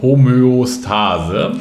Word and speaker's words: Homöostase: Und das hängt zusammen mit der sogenannten, jetzt muss ich es richtig aussprechen, Homöostase. – Hast du ganz richtig ausgesprochen. Ja, habe ich richtig ausgesprochen.